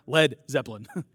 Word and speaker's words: Led Zeppelin: There's a wide gap between Miles Davis and Zed Zeppelin. Can I say it right? Led Zeppelin.